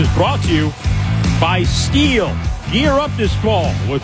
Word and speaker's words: STIHL: That's how you pronounce Steel. It's STIHL, pronounced Steel.